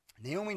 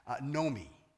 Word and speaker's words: Naomi: Naomi in Hebrew, her name is actually in the Hebrew is pronounced Nomi, not Naomi, Nomi.